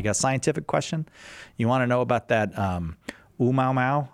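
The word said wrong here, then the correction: Oumuamua (ʻOumuamua): I think that's how you say it. Au mau mau? – Oumuamua. – Oumuamua. Oh, all right.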